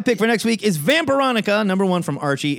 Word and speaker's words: Vampironica: Listen, I'm having a really hard time reading the name of this book and not pronouncing it Vampirotica. – It's Vampironica!